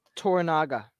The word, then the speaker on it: Toranaga: Toranaga.